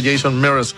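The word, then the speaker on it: Jason Mraz: Pero es Jameson M-R-A-Z, pero se pronuncia Jason Mears, el invitado en el especial musical ahí del trasnoche.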